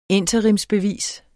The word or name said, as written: interimsbevis